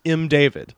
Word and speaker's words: M. David: Apparently there was a guy who worked for him, and it is pronounced M. David. Like it's like because I was like, maybe it's some sort of strange, you know, foreign name that I don't understand the pronunciation of, or maybe it's like, no, it's straight up M. David Lowe.